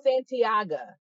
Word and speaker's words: Santiago: Is it Santiga? – Because that's how I've been saying it in my brain. – I forgot his name. It's Santiago. Like Santiago. – Santiago.